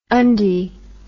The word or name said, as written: undy